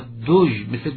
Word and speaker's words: دژم: که ظاهرت دژم و صفتهایی که منفیست با دژ، مثل دژمن، دژ باید با حرف پیش به اصطلاح حرکت پیش تلفظ کرد. دژمن، دژم، همیشه در تلفظ این واژه ها دژمن رو به یاد بیاریم، تلفظ این واژه رو. کلن واژه های به طور کلی صفات منفی با حرکت پیش تلفظ میشن.